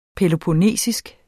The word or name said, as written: peloponnesisk